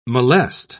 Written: molest

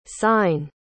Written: cyne

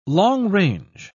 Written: long-range

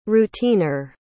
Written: routineer

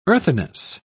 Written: earthiness

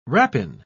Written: rapine

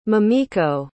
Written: mamiko

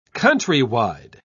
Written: countrywide

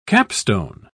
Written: capstone

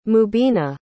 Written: mubina

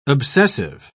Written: obsessive

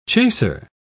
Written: chaser

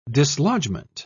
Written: dislodgment

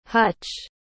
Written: huch

